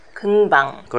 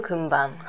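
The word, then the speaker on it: 금방: 금 방 これニュンとピュってちょっと発音しにくいけど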